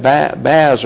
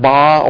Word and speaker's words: bas: But we would want to say that baz or bas, but it's actually pronounced bas or low relief, is smoother and flatter and shows less contrast of lit and shadowed areas.